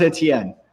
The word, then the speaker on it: Etienne: Um, it, it's pronounced Etienne. Sorry, it's a French name.